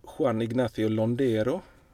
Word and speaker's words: Juan Ignacio Londero: Så spelade Juan Ignacio Londero.